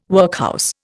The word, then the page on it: workhouse: Indian